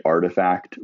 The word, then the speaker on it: RTFKT: Like Nike just bought the company Artifact, which is spelled RTFKT, and makes digital sneakers that are NFTs.